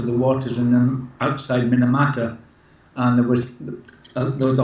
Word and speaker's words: Minamata: It's got Minamata, is that how it's pronounced? Can you tell us about that? – That's Minamata.